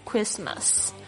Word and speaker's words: Christmas: 好，我们要特别来注意到这个字的拼法是 C-H-R-I-S-T-M-A-S Christmas。那这边你会发现这 CH 的发音呢并不是发 China 这个 Ch 这个声音，而是发 K 这个声音 Christmas。